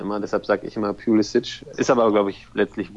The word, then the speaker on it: Pulisic: Und dann habe ich gesagt, ja, wir nennen den hier Pulisic, weil wir eben, sage ich mal, von Luka Modric oder Kovacic oder wem auch immer, diese Endung halt so mit diesem Itch aussprechen. Und da sagte er, ja, interessant, okay, wir sagen in Amerika nämlich Pulisic. Und das ist wohl auch die Aussprache, die er selber favorisiert.